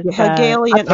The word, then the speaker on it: Hegelian: Hegelian. – Okay. I told you that's how it's pronounced before.